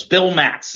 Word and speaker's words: Bill Matz: It's two syllables, Bill Matz.